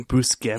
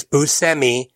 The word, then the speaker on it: Buscemi: Buscemi? – He gets introduced as Buscemi, but when the topic gets brought up, Buscemi admits that while that pronunciation is correct, so is Buscemi.